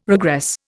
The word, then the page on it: regress: Indian